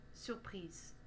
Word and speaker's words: surpris: Il a deux participes passés, un masculin, surpris, et un féminin, surprise. On dit donc il est surpris, he is surprised, mais elle est surprise, She is surprised. On prononce surpris et surprise.